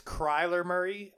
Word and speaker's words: Kyler Murray: You mispronounced his name. It's Kyler Murray because when we beat him again, he's going to cry just like he did the first time.